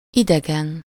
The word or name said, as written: unknown